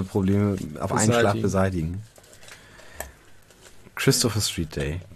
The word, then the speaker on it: Christopher Street Day: Und deswegen müsste es eigentlich Christopher Street Day heißen und nicht Christopher Street Day. Die Betonung muss auf Christopher liegen, denn man sagt es bestimmt nicht, ich fahre nachher noch in die Christopher Street.